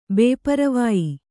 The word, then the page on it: ಬೇಪರವಾಯಿ: Kannada